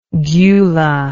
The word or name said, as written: gula